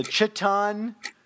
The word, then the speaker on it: Chthon: I guess it's totally fitting that creatures like the, and I'm going to butcher this, the Chthon. – I think you're right.